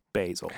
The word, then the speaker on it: Basil: Why would anybody ever tell you that it's pronounced Basil? – Basil. Someone say Basil Hayden.